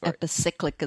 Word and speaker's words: epicyclic: epicyclic engineering. – Epicyclic engineering, or I guess epicyclic is a fine pronunciation.